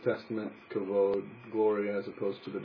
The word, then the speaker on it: kavod: But the word in Hebrew, if you couldn't hear it up here, is kavod.